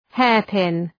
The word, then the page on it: hairpin: ‘heərpın